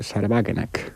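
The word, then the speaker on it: Sahra Wagenknecht: Porque hay, para mí, el experimento de partido más interesante que se está dando en la Unión Europea, por cierto, también putinista, es el de Sarbagenek, o como se diga en alemán, ¿no? – Está bien pronunciado, ¿eh? – Sí.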